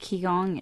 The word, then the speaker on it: Qigong: It's pronounced Qigong.